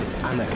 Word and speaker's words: Anneke: Can I just stop you there? It's Anneke. – Bueno, acá es donde Danny interrumpe y me dice que en realidad se pronuncia Anneke y no Anneke.